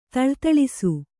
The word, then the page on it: ಥಳಥಳಿಸು: thaḷa thaḷisu